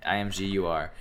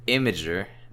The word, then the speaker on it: Imgur: Imgur. And yeah, the results are in. They've been tallied, and the internet has spoken. It's Imgur, not Imgur.